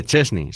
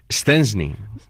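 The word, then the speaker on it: Szczęsny: porque todo el mundo sabe que no se dice Chesney, se dice Stensny.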